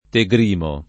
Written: Tegrimo

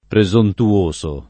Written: presontuoso

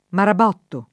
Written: marabotto

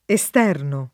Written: esterno

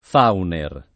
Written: Fauner

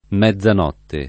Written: mezzanotte

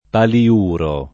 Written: paliuro

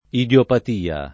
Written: idiopatia

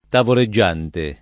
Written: tavoleggiante

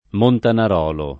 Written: Montanarolo

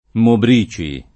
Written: Mobrici

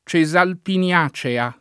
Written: Cesalpino

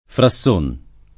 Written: Frasson

